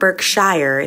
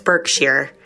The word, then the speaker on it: Berkshire: It's not Berkshire, it's Berkshire.